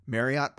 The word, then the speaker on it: Marriott: The reason why I took my previous position with Progressive was so I could get Marriott points. – Sure. – Marriott's points. – Marriott, don't worry. You said it right the first time.